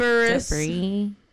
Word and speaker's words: debris: Yeah, I was like, debris. That's how you say it.